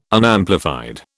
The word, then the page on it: Unamplified: British English